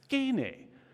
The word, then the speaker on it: skene: Now, if you're familiar with Greek, you know that the Greek does not have an SH pronunciation. You cannot say sh, but it does have an S, s. So when they write it in there, it's skene.